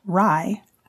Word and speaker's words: RIE: Magda Gerber, the creator of the Respectful Caregiving Approach Resources for Infant Educators, commonly known as RIE, pronounced Rye, was a parent and caregiving educator, infant specialist, and author of the book Your Self-Confident Baby.